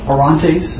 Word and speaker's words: Orontes: As you pointed out, you know, the church center kind of moves from Jerusalem to Antioch, and this is on the Orontes.